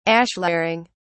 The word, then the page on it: ashlaring: British English